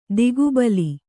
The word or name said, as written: ಧಕ್ಕೆಬಲಿ